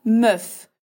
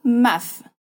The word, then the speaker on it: meuf: Sendo assim, uma palavra como femme, mulher, que o primeiro e se pronuncia como a, se torna meuf, com som de e não maf.